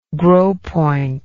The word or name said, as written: gros point